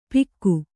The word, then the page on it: ಪಿಕ್ಕು: pikku